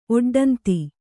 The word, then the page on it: ಒಡ್ಡಂತಿ: oḍḍanti